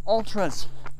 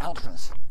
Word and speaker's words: Altra: So I wore my trail shoes, which are Altras. Or as my good, good buddy Kevin likes to say, ultras. Which it still makes me smile. I love that he mispronounces Altra as ultra.